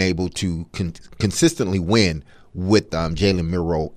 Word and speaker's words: Jalen Milroe: Jalen Milroe played and did what he needed to do as a quarterback to get Alabama that win.